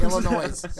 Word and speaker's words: Illinois: Illinois. Illinois. They don't even pronounce the S in their name.